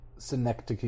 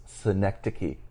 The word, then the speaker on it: Synecdoche: Synecdoche, New York. – Yes, Synecdoche, New York.